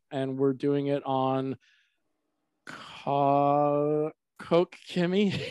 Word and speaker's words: Koch-Chemie: Everybody says Koch-Chemie. So whatever flows off the tongue, but yeah. the actual pronunciation is Koch-Chemie.